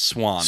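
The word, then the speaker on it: Siuan: Just say it's Swan. – Swan, thank you. – Pretend it's a bird. It's a stupid spelling, but yes, that is her name. Is Swan.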